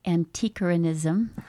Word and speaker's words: antiquarianism: And so what would you say is the difference between antiquarianism? How do I say that? – Antiquarianism. – Antiquarianism and archaeology.